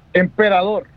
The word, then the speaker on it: Emperador: This is Fremont Brewing and Michelier Brewing got together to do Operador Suave. Operador? How, you can help me with this. How do I pronounce that word? – Operador? – Operador? – Emperador. – Emperador, thank you.